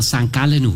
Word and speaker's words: St. Gallen: Ja, für alle in Bhutan oder irgendwo, wo es hört, so spricht man St. Gallen aus.